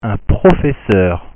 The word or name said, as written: un professeur